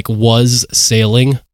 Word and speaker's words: wassailing: Some of these people would even participate in what is known as wassailing, I think is how it's pronounced. – Sure. – It's spelled was sailing put together into one word, but I'm pretty sure it's wassailing is how you pronounce it.